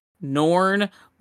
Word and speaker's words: Norn: Norn, like Norm McDonald, but with an N at the end.